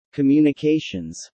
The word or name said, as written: communications